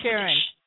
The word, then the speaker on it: Sharon: My name is Sharon. – Sharon. Yeah. How do you spell that, Sharon? – S-H-A-R-O-N.